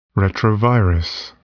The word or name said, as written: retrovirus